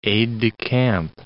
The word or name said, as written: aide-de-camp